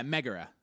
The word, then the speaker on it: Megara: How do you say that? – I think, because, yeah, Megara. – Megara. Okay, who fought Godzilla at some point? – Oh, for sure.